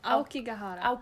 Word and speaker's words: Aokigahara: Nu får du hjälpa mig med uttal, för jag har så svårt. – Aokugihara. Okej, vi kan inte japanska, men Aokigahara. – Just det, där satte du det.